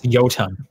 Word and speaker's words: Jotun: And on Xbox Game Pass, they have Enter the Gungeon, Bad North, Jotun Edition, Dirt Rally 2.0, and Killer Instinct Definitive Edition up available now. – I think it's Jotun. I think the J's are pronounced as a Y.